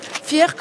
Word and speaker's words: Fier: Fier, comme ça se prononce, hein, c'est Fier. Être fier, voilà.